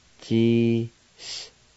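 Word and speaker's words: tsisquoquo: G-s-quo-quo.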